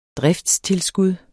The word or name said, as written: DRIFTSTILSKUD